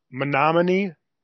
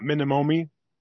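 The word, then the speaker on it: Menomonie: All right, I'm only interrupting this because it's pronounced Menomonee, not Minimomi.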